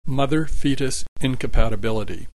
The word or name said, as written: mother fetus incompatibility